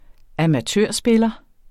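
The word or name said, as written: AMATØRSPILLERE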